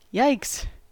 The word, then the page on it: yikes: American English